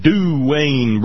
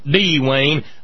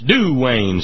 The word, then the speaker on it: Duane: Duane Bridges. That's Duane, not D-Wayne. That's Duane. Say it right, just like he said, "Do Lord, oh, do Lord, oh, do remember me, up away beyond Galilee."